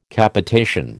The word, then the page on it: Capitation: American English